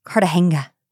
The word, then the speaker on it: Cartagena: Cartagena.